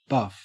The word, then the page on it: buff: Australian English